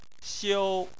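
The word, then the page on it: xiu: xiu1; tone 1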